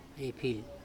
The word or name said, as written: epHIl